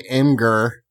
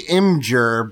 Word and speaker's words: Imgur: I got a bunch of people giving me shit for how I say Imgur. But that's what it looks like. – I mean, it's how they spell it, yeah. – Apparently it's supposed to be Imgur, but...